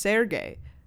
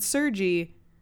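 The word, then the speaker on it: Sergey: So I feel like his name should be pronounced Sergei, but yeah, he pronounces it Sergi.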